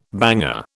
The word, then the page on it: banger: British English